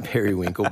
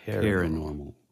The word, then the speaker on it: paranormal: You pronounce it like it's periwinkle, but it's paranormal. Wait, no, that's right. – You got me saying, is it perinormal or paranormal? I think it's paranormal.